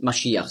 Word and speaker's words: Maschiach: Christus ist einfach die Übersetzung von Hebräisch Maschiach, und Messias, das ist einfach die Aussprache, die Griechen konnten nicht Maschiach sagen, weil es gibt ja kein Sch-Laut im Griechischen.